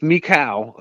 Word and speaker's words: Michal: and how his wife Michal, you know, it's spelled Michael, but Michal,